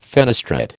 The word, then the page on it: fenestrate: American English